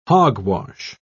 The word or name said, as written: hogwash